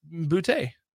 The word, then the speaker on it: Boutte: It's Boutte.